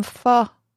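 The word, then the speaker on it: phở: Maybe he wants to make you some pho. Did I say that right? Oh, yeah.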